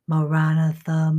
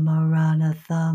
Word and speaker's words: Maranatha: And it's repeated for equally stressed syllables. Maranatha, Maranatha, Maranatha, Maranatha.